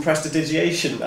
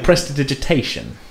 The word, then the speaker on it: prestidigitation: Well, I was doing some prestidigitation. I believe I just pronounced that correctly. – Not quite, maybe, but close. – How do I pronounce that? Say it again. – Prestidigitation.